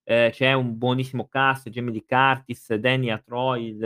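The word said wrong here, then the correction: Aykroyd: eh, Acroid, eccetera, che è quello che ha fatto anche Bruce Brad.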